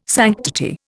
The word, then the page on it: sanctity: Indian